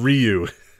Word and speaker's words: Ryu: How do you say that? It's Ryu. – Is that Ru? – It's not Ru.